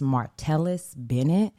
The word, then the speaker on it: Martellus Bennett: Former NFL star, I believe his name is pronounced Martellus Bennett,